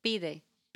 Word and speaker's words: pide: Pide. – Pide? How do you spell that? – P-I-D-E. – P-I-D-E.